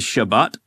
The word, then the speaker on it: Shabbat: And the word Sabbath, the Hebrew word, I won't say it right, is Shabbat.